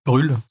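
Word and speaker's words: Brühl: et évidemment comment il s'appelle Daniel Bull. – Brûle, ça se prononce.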